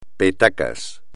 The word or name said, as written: petacas